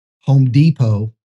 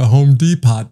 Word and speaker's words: Depot: Case in point, Home Depot is a silent T. But if you're deaf, it's Home Depot.